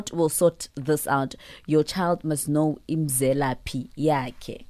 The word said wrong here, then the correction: imzelaphi: Hope I pronounced that right. – No. – How do you pronounce it? – How do you spell it? – I-M-Z-E-L-A-P-H-I. – Oh, Mzelapi, yes. It's just your pronunciation is really bad. Mzelapi.